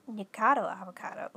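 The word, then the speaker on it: Nikocado Avocado: Yeah, Nick Avocado. That's right. Nicado Avocado. Ooh, I was saying it wrong.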